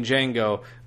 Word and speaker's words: Django: Yeah, but so Quentin Tarantino did the same thing, and there's actually a scene in that movie where Jamie Foxx playing Django goes up to the bar and introduces himself to this Italian guy. – What's your name? – Django. – Can you spell it? – D-J-A-N-G-O. The D is silent.